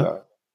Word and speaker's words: Glöckler: Die spricht man Glöckler oder Glöckler aus? Glöckler? – Glöckler.